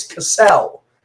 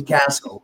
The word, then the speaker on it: Cassel: In my entire year in professional wrestling, motherfuckers from East Coast to West Coast have thought it was Cassell, as opposed to Castle, which is its actual pronunciation.